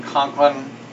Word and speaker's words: Conklin: C-O-N-K-L-I-N? – Mm-hmm.